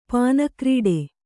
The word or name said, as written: ಪಾನಕ್ರೀಡೆ